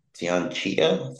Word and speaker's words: Tianxia: Tianxia.